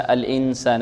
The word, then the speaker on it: الإنسان: الإنسان عندنا نون ساكنة وبعدها سين، حكمها ماذا؟ إخفاء، إخفاء.